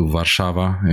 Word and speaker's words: Warszawa: And this week I went to Warsaw, to Warszawa in Polish pronunciation for the workshop I was running with my wife.